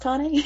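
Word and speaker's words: Tané: And then you've got Tane, I think it's pronounced Tane, who's training to be a dragon rider on the other side of the world.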